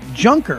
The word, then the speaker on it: Juncker: Well, how would you pronounce J-U-N-C-K-E-R? Junker, right? Jean-Claude Juncker. And this cat is full tilt bozo.